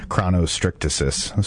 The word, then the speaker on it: chronostrictesis: We've gone back and forth on the pronunciation. I've been going chronostrictesis, sort of like photosynthesis. But okay.